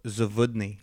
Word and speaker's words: Zawodni: Well, yeah, my last name is Zawodni. You pronounce the W as a V.